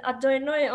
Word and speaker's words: Adonai: One is Adonai, and it's actually spelled Yehovah, which is like Jehovah, but we just pronounce it Adonai only when you say a blessing.